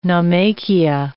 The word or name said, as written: naumachia